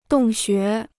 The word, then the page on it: 洞穴: dòng xué